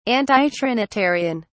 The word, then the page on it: antitrinitarian: British English